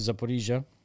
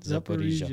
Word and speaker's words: Zaporizhia: We drive to Zaporizhia. That's how you pronounce it. – Mm, there you go. – Zaporizhia. Zaporizhia.